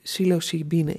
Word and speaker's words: psilocybine: En er zit dus psilocybine in. Zeg ik dat goed? Ja.